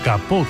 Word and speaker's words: capot: El alemán tomó prestado ese kaputt en el siglo XVII del francés capot, hoy pronunciado capot concretamente,